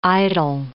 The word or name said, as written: idyll